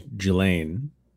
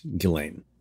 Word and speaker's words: Ghislaine: It was our understanding that it was Ghislaine, but the preponderance of authority suggests to us now that it's Ghislaine.